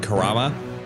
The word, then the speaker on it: Kurama: So they have announced the casting of, so I don't butcher that, Kurama. – Yeah. – Will be played by Japanese actor and voice artist Jun Shiso. – You were worried about butchering Kurama? – I said that right.